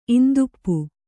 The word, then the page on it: ಇಂದುಪ್ಪು: kannada